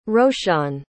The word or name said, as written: roshaun